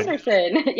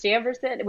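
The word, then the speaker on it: Chambourcin: Chambourcine? How do you say that one? Chamberson. Yes, Chamberson.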